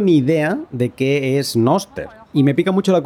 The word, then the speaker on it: Nostr: Ese algo es Noster. Se escribe N-O-S-R-T.